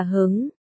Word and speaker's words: hứng: Đào Cán Tiên xen vào, thế còn ba chữ Hưng chi thần là nghĩa làm sao, Đào Diệp Tiên đáp, Hưng đây đọc là hứng, hứng là cao hứng, hứng chi thần là nói tinh thần rất cao hứng, ồ, Dương Công Tái.